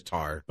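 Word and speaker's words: Tár: Um, tor, tar. There's an accent over the A. I don't know how he pronounces it. It's tar, but uh, so, uh, yeah. just tar.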